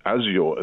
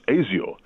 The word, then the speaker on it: azure: In fact, there's a very crackly a version of this on YouTube in which she actually pronounces Azure as Azure.